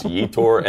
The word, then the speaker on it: Yetur: I was told his name rhymes with detour. So I guess Yetur. And then someone was like, no, it doesn't.